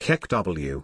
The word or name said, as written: kekw